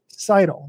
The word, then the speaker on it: Seidel: I have a podcast where we talk about her regularly, and it's Seidel.